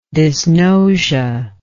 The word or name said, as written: dysgnosia